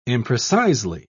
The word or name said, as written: imprecisely